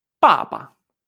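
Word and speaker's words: papa (爸爸): Papá en chino se dice papa. Fíjate, pa y luego pa, pa, pa.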